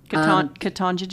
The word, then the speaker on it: Ketanji: Ketanji Jackson.